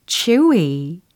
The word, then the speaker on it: chewy: 예, 쫀득쫀득한 식감, 영어로는 chewy chewy 이 단어로 나타내시면 좋아요. chewy 기억하시고 it's chewy, it's chewy 추위하고 달라요. 겨울에 추위하고는 다른 바, 발음입니다.